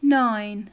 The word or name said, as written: nine